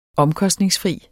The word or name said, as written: OMKOSTNINGSFRI